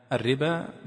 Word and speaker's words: الربا: لكن قبل أن نبدأ، كان ذكر البيضاوي رحمه الله تعالى في قوله تعالى الذين يأكلون الربا مسألة، وهي كتابة كلمة الربا بالواو، مكتوبة في المصحف الربا باء بعدين واو بعدين ألف، وهي ليست مطابقة للنطق، وهي الربا باء ألف، لكنها مكتوبة في القرآن الربو وبعدين ألف بعدها. ومثلها الصلاة والزكاة وكلمات معدودة.